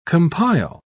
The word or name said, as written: compile